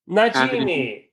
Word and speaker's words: Najimi: Kathy Naimi, I am not sure if I'm saying that right. – Najimi.